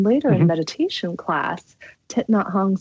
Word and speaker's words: Thich Nhat Hanh: How do I say his name again, Dan? I don't want to butcher it. – Thich Nhat Hanh. – Thich Nhat Hanh. Thank you.